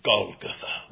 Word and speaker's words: Golgotha: It's Golgotha, with all the gore and all the ugliness, the hard G.